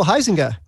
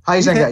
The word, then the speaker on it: Huizenga: I think in terms of comics, Kevin Huizenga, I can't remember if I'm saying his name right. – Oh, Huizenga. – Huizenga, yeah.